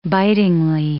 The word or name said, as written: bitingly